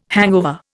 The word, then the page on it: hangover: Indian